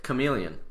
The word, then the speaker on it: chameleon: He's a Charmeleon, man, or Carmeleon, I don't know how to say it. – Chameleon.